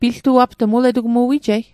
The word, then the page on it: piltuapt'g: Mi'gmaq